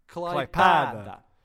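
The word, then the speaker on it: Klaipėda: Klaipeda. – Oh God! No. It's not an E, it's an... Okay, imagine that in place of that E, there's an A, and it's a long A. – Klaipeda.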